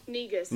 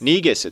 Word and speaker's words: Negus: Negus. – Negus, it's pronounced. Not negus. Negus. Negus, like Negan in The Walking Dead.